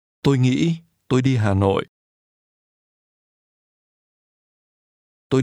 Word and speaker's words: nghĩ: Tôi nghĩ, tôi đi hôm nay. – I think. – Hãy nghe và nhắc lại. – Tôi nghĩ. Nghĩ. Nghĩ. – Think is pronounced with a high rising broken tone.